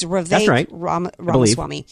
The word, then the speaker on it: Vivek Ramaswamy: Anyway, I think it was Thursday or Friday when he had Vivek Ramaswamy on, I can never pronounce it. It's Vivek Ramaswamy on his show, and Ramaswamy gave it to him.